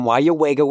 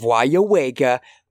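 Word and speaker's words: Weyauwega: They have the Wayawega cheese from Wayawega, Wisconsin, with the correct pronunciation of Wayawega on the package. That way, an Illinoisian like me can say Wayawega and sound great and get that smoked provolone.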